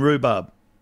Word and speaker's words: rhubarb: What's the silent letter in rhubarb? Joey? – H.